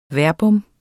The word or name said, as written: verbum